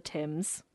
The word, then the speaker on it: Thames: It says, as the Adventure Galley sailed down the Thames. Right. – You said it right. – You nailed it.